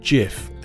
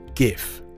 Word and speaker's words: GIF: And yes, before I get lots of performative emails taking a hard pass on my pronunciation of GIF, it is pronounced GIF and not GIF. No, it isn't. GIF stands for Graphics Interchange Format. You don't say graphics, do you?